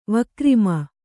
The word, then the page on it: ವಿಕ್ರಮ: kannada